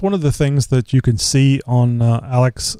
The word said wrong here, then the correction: Alexa: I don't know if I'm saying it right. It's Alex with an A dot Alexa. – Org. Alexa. Thank you.